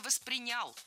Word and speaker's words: воспринял: Ну, если вот мне по-простому, да, объяснить, вот я бы это так воспринял. – Да? – Что да. – Это не приставка. – Было сказано «воспринял», но это неверно. Правильный вариант – «воспринял». Ударение в глаголе ставим на второй слог.